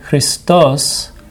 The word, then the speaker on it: Christos: And by the way, if you say Christos with the accent on the os, that's only Jesus Christ, but people can have the name Christos.